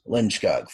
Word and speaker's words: Lindskog: Yes, Lindskog, folks.